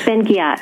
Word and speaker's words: Ben-Ghiat: uh, ben Ghiat.